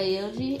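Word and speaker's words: Yogi: By the brand Yoji. – Yeah, Yoji.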